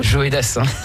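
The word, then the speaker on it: Joe Dassin: Je ne connaissais pas cette manière de prononcer Joé Dassin. – C'est Joé Dassin ? – Joé Dassin.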